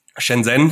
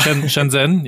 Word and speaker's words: Shenzhen: Shenzhen, ja. – Shenzhen, ja.